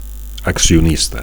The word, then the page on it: accionista: Catalan